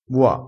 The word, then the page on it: bua: dialettale